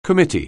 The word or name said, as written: committee